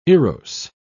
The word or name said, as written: erose